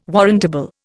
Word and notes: warrantable: Indian

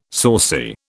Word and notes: saucy: British English

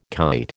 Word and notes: kite: American English

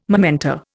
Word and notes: momenta: Indian

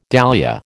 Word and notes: dahlia: American English

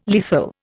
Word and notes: lethal: Indian

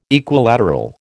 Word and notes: Equilateral: American English

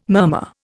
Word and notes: murmur: Indian

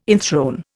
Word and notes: enthrone: Indian

